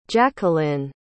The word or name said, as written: jacquelyne